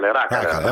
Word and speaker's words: Racale: Ho detto bene Racale o Racale? – Racale.